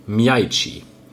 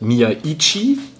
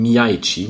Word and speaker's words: Miyaichi: Ausgesprochen wird er, wenn man zumindest Philipp Herwagen glauben darf, Miyaichi. Nicht wie ich oder auch Wolf das oft ausspricht, Miyaichi, sondern Miyaichi mit einem durchaus fließenderen Übergang zum ersten I.